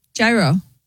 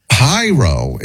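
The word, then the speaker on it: Jairo: Did you guys catch how Doogie pronounced the name J-A-I-R-O, a frequent emailer? – Gyro. – Even, I think it's pronounced Hyro. – No. – Even though it begins with a J? – J-Ro is what she said. Yeah. Hyro is the guy who emailed, of course, and Gene writes...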